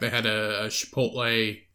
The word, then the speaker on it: Chipotle: Like chip. Like chocolate chip. Chipotle.